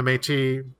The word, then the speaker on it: Métis: We call them the Métis mostly in English. We don't pronounce the S in English so much,